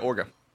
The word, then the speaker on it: Orgarhythm: I always thought it was Orgarhythm, like ogre, a big ogre, and ogre then rhythm. Game. But no, it's spelled weird. – Yeah, Orga. – Orga.